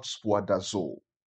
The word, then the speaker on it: spoudazo: Now the Greek word for the word there, study, is the word spoudazo. Spoudazo. And I'll spell that for you. So it's S-P-O-U. S-P-O-U. D-A-Z-O.